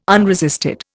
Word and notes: unresisted: Indian

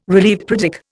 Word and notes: relieved: Indian

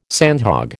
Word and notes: sandhog: American English

